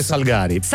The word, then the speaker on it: Salgari: Allora, l'accento sulla seconda A. – Allora, il dubbio è, accento sulla seconda A o sulla prima A? In quel caso sarebbe Salgari. A voi. – Treccani dice Salgari.